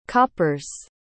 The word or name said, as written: copper's